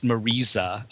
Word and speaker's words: Marisa: And all four of the major stars of the film, Liza Minnelli, Joel Grey, Marisa Berenson, apparently it's pronounced Marisa. People made a point of saying it that way.